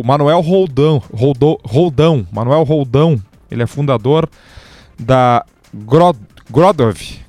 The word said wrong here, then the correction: Growdev: Perdoe se eu pronunciei errado, Grodev. É isso, Manuel. Bem-vindo. Boa noite. – Boa noite, Debona. Boa noite aos ouvintes da Gaúcha. É Growdev, né? É um Growdev ali, né? Acentuei errado aqui, né?